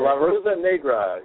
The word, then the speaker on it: La Rosa Negra: L.A. Rose Negra. Did I say that right? I think I said that right. – La Rosa Negra, yes.